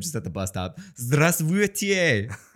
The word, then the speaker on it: Здравствуйте: So and the the stress will be on the first sound pattern. So it'll sound like this. Здравствуйте. Oh god. Здравствуйте. On the second syllable, uh, try to enunciate the I sound more.